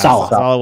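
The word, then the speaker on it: Saleh: Yeah, because with Robert, how do you pronounce Sala. – It? – Yeah, Sala went off to the Jets.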